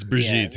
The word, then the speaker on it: Brigitte: Isn't it Brigitte? – It's Brigitte, yeah.